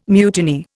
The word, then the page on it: mutiny: Indian